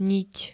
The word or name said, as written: niť